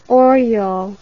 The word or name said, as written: oryol